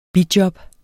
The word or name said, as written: BIJOBS